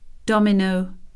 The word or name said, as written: dominoes